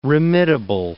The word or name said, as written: remittable